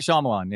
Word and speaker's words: Shyamalan: How do you say that director's last name? Shyamalan? – Shyamalan? – M. Night. Sounds right. – Yeah, M. Night Shyamalan, yeah.